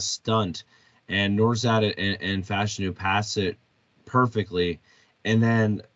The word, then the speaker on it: Fashanu: I, I Olu or yeah, Fashanu Gotta make sure we say that right now because we keep saying wrong for so long.